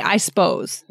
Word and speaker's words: suppose: We kind of drop that sup, that one syllable there, and we say, I suppose.